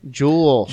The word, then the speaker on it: Joelle: Joelle Crandall? – Jewel. Like a diamond jewel.